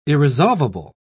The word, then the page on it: irresolvable: British English